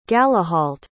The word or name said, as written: galahalt